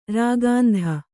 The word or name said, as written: ರಾಕೇಂದು